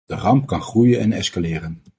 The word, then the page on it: ramp: Dutch